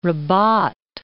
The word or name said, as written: Rabat